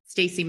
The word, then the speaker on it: Stacy: And you can always follow, you know, if anybody wants to follow my Instagram, it's @_ and then my full name, Stacy Madison.